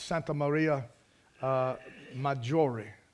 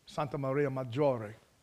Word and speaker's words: Santa Maria Maggiore: It's pronounced in Latin. It's Santa Maria Maggiore. Santa Maria Maggiore.